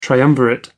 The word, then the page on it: triumvirate: American English